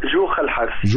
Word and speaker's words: جوخة الحارثي: يعني هو أنا بنطق اسمها كده صح، جوخة الحارثي؟ – لا بتنطق جوخة الحارثي. – جوخة الحارثي. – جوخة الحارثي. انما طبعا صح، احنا الجيم القاهريه ما بتفتحش تعطيش، انما هي تنطق في المجتمع العماني الدكتوره جوخه.